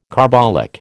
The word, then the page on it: carbolic: American English